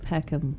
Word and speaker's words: Peckham: We have the awesome Missy Peckman from Access Bars. – Yeah, you say it like Peckham, like bend it like Peckham. – Peckham. Oh, so yeah. Sorry. – Peckham instead of Beckham.